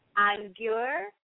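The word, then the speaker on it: angur (अंगूर): And that is spelled A-N is in Nathan, G-U-R-A. So it's spelled angura, but it's pronounced angur. – Angura. – Yeah, you can. It's actually, you can pronounce the G. It's angur. So angur. – So A-N-G-U-R-A.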